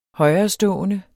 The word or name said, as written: højerestående